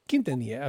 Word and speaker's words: Quintanilla: Quintanilla? – Pretty close. – Give it to me. – Quintanilla. – Oh, sorry, well, you know, you just Spanish it up even a little more, but Quintanilla.